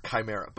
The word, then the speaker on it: chimera: And I don't care if she brings up I Am Number 4 with the Chimeras, with the AE. It's chimera.